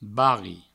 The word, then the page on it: barri: Provençal